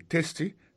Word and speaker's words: tasty: Food, F-O-O-D, ça veut dire manger. Tasty, T-A-S-T-Y, ça veut dire goût. Goût dans bouche. T-A-S-T-Y, vous prononcez le testi, ça veut dire goût dans bouche.